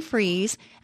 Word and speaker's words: Fries: I'd love for you to connect with me by going to Sue Freeze, and that's spelled like fries, one word, .com.